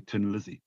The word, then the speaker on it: Thin Lizzy: You know, the Irish pronunciation of thin is tin, where you say Tin Lizzy.